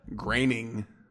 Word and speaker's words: Groening: Groening. – So, did I say it wrong? – Yeah, that's all right. Okay. I said it wrong for a long time. I got corrected on it. – Either way, he's still a time traveler. – How is it said? – Graining. – Graining? – Graining?